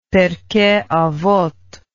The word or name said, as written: pirkeavoth